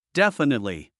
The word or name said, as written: definitely